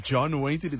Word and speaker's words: John Waite: John Waite. I don't know if I'm pronouncing it right, but John Waite. – John Waite, that is spot on. That is correct.